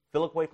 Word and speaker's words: Filioque: 1054 caused a split in the church, and it's called the, do you pronounce that Filioque clause? Filioque clause? Yeah. Yep.